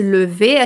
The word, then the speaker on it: lever: Atenção na pronúncia desse lever aqui, que esse E aqui é meio morto, lever.